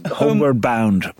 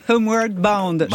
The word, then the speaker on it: Homeward Bound: Homeward Bound. – Homeward Bound.